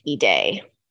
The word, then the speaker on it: Ide: And the author is Joe, J-O-E. I-D-E, and it's pronounced E-Day.